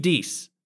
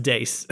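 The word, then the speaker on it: Deace: And this week I want to give that to Steve Deese. And actually, I think it's pronounced Dace, Steve, but it looks like Deese.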